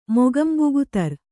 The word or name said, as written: ಮೊಗಂಬುಗುತರ್